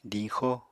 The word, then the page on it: diñxo: apatani